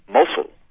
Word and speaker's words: Mosul: It can be pronounced a number of different ways, but one way is Mosul, the other is Mosul.